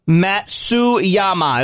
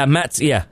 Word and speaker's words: Matsuyama: Matsuyama is what the text line is. – Yeah, Matsuyama.